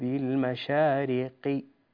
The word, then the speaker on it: المشارق: كلمة المشارق أنت نطقت الحرف القاف قريبا من الكاف، المشارقي لا، أولا حرف القاف لا يهمس، ثانيا ينطق هكذا برب المشارقي كما تقول المستقيم، كلمة المشارق وأيضا كلمة بمسبوقين لابد أن تعتني فيها بحرف القاف، تقول بمسبوقين وليس قين قي لا.